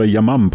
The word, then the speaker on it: Yamam: Mike, it would seem the unit that, for example, that was involved with the special ops, it's called the Yamam Police Counterterrorism Unit. Have I pronounced that correctly? – Correctly, exactly.